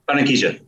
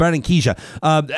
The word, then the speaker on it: Barranquilla: Is it Barranquilla? Is that how you say it? – Barranquilla. – Yep. Barranquilla.